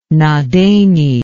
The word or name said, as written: Nadene